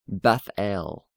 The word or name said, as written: Bethel